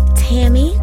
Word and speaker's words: Tammi: My name is Tammy Adair. – How do you spell that? – I spell my name T-A-M-M-I.